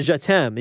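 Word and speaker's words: Jatemme: he brings this terrifying swagger to Je T'aime. And you have to think of, like, I love you, right? Like in French, Je T'aime, right? That's how you say his name.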